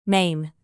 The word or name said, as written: mane